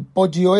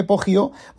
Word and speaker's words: Poggio: Eh, comienza con una escena del Poggio este, Poggio, bueno, se escribe P-O-G-G-I-O, B-R-A-C-C-I-O-L-I-N-I,